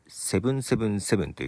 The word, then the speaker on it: 777: はい、シルクソニックで、セブンセブンセブンという曲でした。数字で 777 と書いてね、セブンセブンセブンとこの曲は読ますようです。